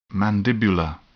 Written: mandibular

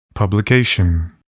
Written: publication

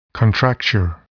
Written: contracture

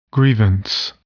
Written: grievance

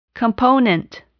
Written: component

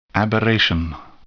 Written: aberration

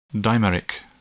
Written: dimeric

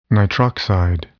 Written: nitroxide

